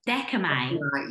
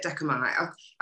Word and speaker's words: Dechomai: Dechomai. – Yeah, that's it, Dechomai. I was almost there when I was practicing, but I just thought, you know, you can give it that flavour, Dechomai.